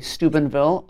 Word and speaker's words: Steubenville: And so the first is, I'm nervous about saying it correctly, Steubenville in Ohio.